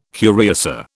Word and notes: curiosa: British English

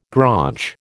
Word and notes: graunch: American English

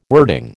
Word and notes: wording: American English

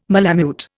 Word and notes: malamute: Indian

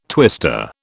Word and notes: twister: British English

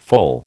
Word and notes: foal: American English

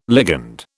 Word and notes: ligand: British English

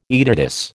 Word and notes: aetatis: American English

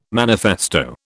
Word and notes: manifesto: British English

